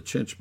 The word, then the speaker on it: chinch: And she didn't pronounce it quite right, so I said, no, it's C-H-I-N-C-H, chinch bugs.